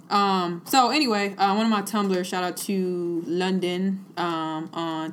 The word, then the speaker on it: Tumblr: Tumblr. Ooh, I said it wrong. You're right. Tumblr.